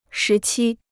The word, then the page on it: 时期: shí qī